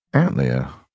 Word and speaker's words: Antlia: The constellation name Antlia is pronounced Antlia, motherfucker.